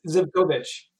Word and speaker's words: Zivkovich: Well, it's Zivkovich. – Yeah, right. – It's just blending. It's like maybe blending the syllables a little bit more, but that's as close as you're going to get.